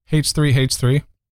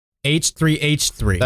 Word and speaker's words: H3H3: H3H3. – H3H3.